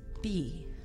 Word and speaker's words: bhi: The ancient Egyptian word for bee was bee. I know that sounds kind of funny. – How do you spell it? – B-H-I, meaning to quiver.